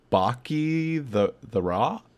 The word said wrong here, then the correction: Bocchi the Rock: I think it's pronounced Roke. – Roke? – Yeah. – Baki the Roke? – It's Bochy the Rock. – Bochy the Rock, which is, wow, what an amazing show.